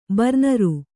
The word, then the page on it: ಬರ್ನರು: Kannada